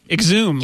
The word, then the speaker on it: Xoom: is they never said how to pronounce the thing. X-O-O-M. – Right. Exhume, yeah. Like exhume the body.